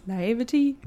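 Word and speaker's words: naïveté: Naivety? – Naivete? – Yeah, thank you.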